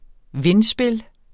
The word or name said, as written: vindspil